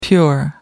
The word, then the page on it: pure: American English